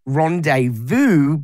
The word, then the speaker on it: rendezvous: Luckily, I know that it is rendezvous, but yes, it looks like rendezvous.